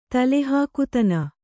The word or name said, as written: ತಲೆಹಾಕುತನ